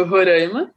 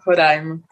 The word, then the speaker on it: Roraima: E a pronúncia correta, né, de Roraima pode ser tanto Roraima né, ou Roraima, né? As duas formas são aceitas, embora os roraimenses prefiram a pronúncia Roraima, em razão da origem indígena, quanto de sua proximidade com a pronúncia espanhola, né, idioma mais presente na região.